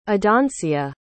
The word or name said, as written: adoncia